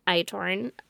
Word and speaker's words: Eindhoven: So, Cora's body was found in a ditch near Eindhoven on June 22nd. Side note, I know I'm saying that wrong. – I think you're nailing it.